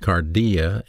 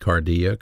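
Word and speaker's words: cardia (καρδία): It's in the mind, pronounced nous, and the heart, pronounced cardia in the New Testament, cardia, cardiac, cardiology.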